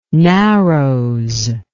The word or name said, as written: narrows